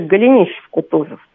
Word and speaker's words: Голенищев-Кутузов: Вот нашла у Михаила Голенищева-Кутузова, кстати, вот правильно произносить вообще Голенищев-Кутузов.